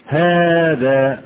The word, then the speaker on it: هذا: هذا في ألف بعد الهاء، أين هذه في الكتابة؟ هؤلاء في ألف بعد الهاء، أين هذه في الكتابة؟ تمام؟